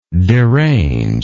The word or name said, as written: deranger